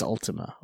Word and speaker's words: Ultima: U-L-T-I-M-A. But they call her Tima. – Hmm. – But her full name is Ultima, or Ultima, because she's Spanish.